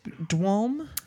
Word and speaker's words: dwalm: So now that was Dwalm. – Yeah, correct? – That's what you said it right.